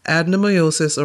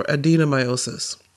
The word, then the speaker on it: adenomyosis: And the reason I'm spelling it is because apparently there are two ways of pronouncing it, adenomyosis or adenomyosis. So anyway, I had never heard of adenomyosis until just this month.